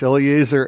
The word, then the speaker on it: Eleazar: sons of Eleazar. There, I pronounced it in English. Eleazar and Ithamar.